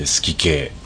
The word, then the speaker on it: 数寄景: えー数に奇数の木に景色の景で好き系って読むんですけど、